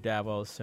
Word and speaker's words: Davos: And that is an article entitled The Hidden Automation Agenda of the Davos Elite. Is that how you say it? Davos? Davos? D-A-V-O-S.